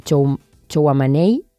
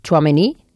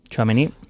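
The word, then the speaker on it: Tchouaméni: L'uruguaià es discuteix amb Chouameni, no ho he pronunciat bé. – Chouameni. – Chouameni.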